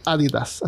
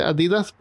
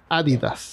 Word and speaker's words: Adidas: Y la otra marca bien popular es Adidas, que se pronuncia Adidas o sea, se debería pronunciar Adidas, así es el nombre original. Adidas. Es, no, es porque el fundador se llamaba Adi Dassler. Ah. Adi So, pues, nosotros lo pronunciamos como Adidas. Ajá. – gente le dice Adidas, pero es Adidas. – Adidas. Se acento en la A.